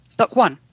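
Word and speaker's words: Dokk1: Pronounce the name of the library. – Dock One. – Dock One Library. – You could have got that, Ed. – I know. – D-O-K-K.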